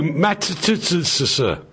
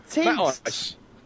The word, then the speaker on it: Matisse: No, Matisse. – Oh, Matisse. – You talk. – I'll tell you what we're like. What? We're like a geriatric boy band. It's Matisse.